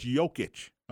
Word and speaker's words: Jokic: I don't know how to pronounce it. Jokic. Oh, no, it's Jokic. No, it's Jokic.